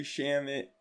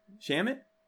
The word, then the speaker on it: Shamet: But like, even Landry Shamet, or sorry, I probably pronounced that. Go ahead, pronounce it right, Gerb. – No, you was right. – Oh. Shamit? – Yeah.